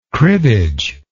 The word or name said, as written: cribbage